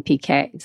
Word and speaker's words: Piquet: P and K is how we pronounce my last name, PK.